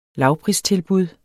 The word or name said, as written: lavpristilbud